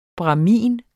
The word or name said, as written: bramin